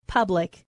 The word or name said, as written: public